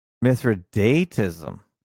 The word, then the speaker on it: Mithridatism: And he just put out a good one today, or yesterday, about Mithridism. Have you heard of this? Mithridatism. Let's see if I'm pronouncing that right. Mithridatism. – Mithridatism.